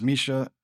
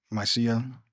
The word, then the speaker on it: Mysia: In verse 7, it reads, to the northwestern province of Asia Minor, known as, I've seen it pronounced Mysia or Mysia, both ways.